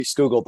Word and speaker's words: Scougal: Tell me about this book, Henry Scougal. I think I'm pronouncing his name correctly. – Yeah, Henry Scougal, The Life of God in the Soul of Man is the title of the book.